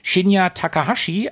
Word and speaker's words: Shinya Takahashi: Dabei spielte der EAD-Mitarbeiter Shinya Takahashi eine wichtige Rolle. Ich glaube, ich habe den Namen ganz gut ausgesprochen. Ja, habe ich auch gedacht.